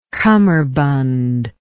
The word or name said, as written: cummerbund